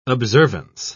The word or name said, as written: observance